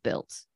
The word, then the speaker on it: Bild: It's B-I-L-D, but I believe they pronounce it built. Like the D is pronounced with a T.